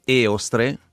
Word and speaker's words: Eostre: con l'accento sulla E, Eostre,